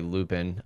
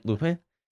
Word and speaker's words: Lupin: It's called Lupin, which is spelled L-U-P-I-N, so you can just say Lupin.